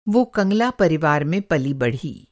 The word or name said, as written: कंगला